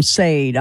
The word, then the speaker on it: Sade: Yeah, I said it wrong on live on radio. I called him Sade. I'm like, oh, I never lived that one down. So, yeah, Sade.